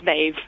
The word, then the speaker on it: Maeve: My name is Maeve. – Maeve? – Oh, Maeve. – Maeve. – Oh, Maeve. – It rhymes with Dave.